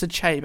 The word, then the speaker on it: Achebe: I think I said Achebe a lot last time. Probably shouldn't have Googled the pronunciation after we recorded part one, but it's Achebe, and I apologise if I offended anyone.